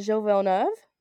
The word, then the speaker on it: Gilles Villeneuve: We're at Gilles Villeneuve. – One more time? – I don't know if I said it right. – I don't think it's called Gilles.